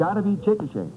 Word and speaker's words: Chickasha: C-H-I-C-K-A-S-H-A. I said Chickasha. – Oh, Mr. McNeil. – That is not right, I've taken. No, no. But the name is pronounced Chickasha.